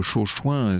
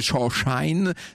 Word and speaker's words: Chauchoin: Il faut dire que Chauchoin, c'est pas très joli, puis c'est surtout très difficile à prononcer pour les Américains, qui dit Chauchine, c'est-à-dire le cirage, vous savez, le cirage à chaussures.